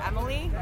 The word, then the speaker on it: Emily: Sure, Emily, E-M-I-L-Y.